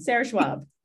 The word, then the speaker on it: Sarah Schwab: And you got it right on, Sarah Schwab.